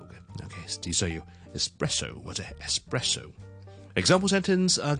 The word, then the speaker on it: espresso: Technically 嚟讲咧，呢个唔系真系一个英文字嚟嘅，系一个意大利文字咧。本身系 espresso 一个特浓嘅咖啡啊，我哋系 espresso，E S P R E S S O。